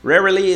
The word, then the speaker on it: rarely: Essa palavra é muito difícil para alguns, mas tem que fazer porque tem, são dois R's, então você tem que fazer esse som. Rarely. Para fazer a palavra raramente. Qualquer coisa está lá no site, lá no nosso post. Rarely is spelled R A R E L Y. Rarely.